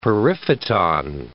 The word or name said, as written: periphyton